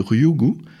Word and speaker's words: Ryugu: Alors on est toujours dans l'actu avec vous bien sûr, et on a découvert les briques de l'ADN sur l'astéroïde Ryugu, je ne sais pas si je prononce Ryugu. – Bien. Parfait, bon accent, merci.